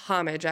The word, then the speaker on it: homage: It's homage, actually. – Homage? – You say the H.